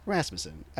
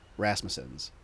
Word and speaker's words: Rasmussen: Rasmussen. Everyone's like, Rasmussen, R- I was like, I grew up with family friends, Rasmussen's.